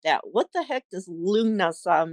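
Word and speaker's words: Lughnasadh: And I'm like, I love saying it, Lunasa. But it's basically, Lu is it within that. So L-U-G-H, pronounced Lu.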